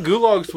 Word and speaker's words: gulag: I thought gulags was German anyway. – Not Russian? – Yeah, how do you spell that? – G-U-L-A-G. Gulag. Kind of like goulash.